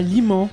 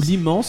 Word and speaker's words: Limans: Nous sommes à Limans, ou Limans, ce sont les prononciations, dans les Alpes de Haute-Provence, dans un superbe gîte.